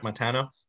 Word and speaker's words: Montano: Montano? – Yeah.